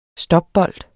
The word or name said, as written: stopbold